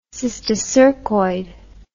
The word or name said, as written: cysticercoid